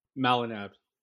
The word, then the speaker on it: Molineb: Am I saying that right, Chris? – Yeah, it's close enough. It's kind of butchered all the time, so that's pretty close. – I would love to hear the correct pronunciation. – Molineb. – Molineb. – Just exactly as it's spelled.